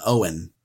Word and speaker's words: Eoin: It's an old Irish name. E-O-I-N, Owen. The E is silent.